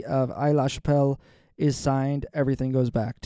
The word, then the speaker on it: Aix-la-Chapelle: It's pronounced X. – Oh, it's Aix-la-Chapelle. – Yes. – Thanks for looking that up. – Yeah. – Aix-la-Chapelle. I just feel like that's French, and like the X would be silent, like Bordeaux.